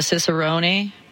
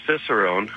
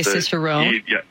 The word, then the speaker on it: Cicerone: How did you become Alberta's first, am I pronouncing it right, a Cicerone? – It's just Cicerone. – Okay, Cicerone.